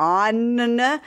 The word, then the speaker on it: Onn: It's something called Own, O-N-N. Like, how do you say that? Own.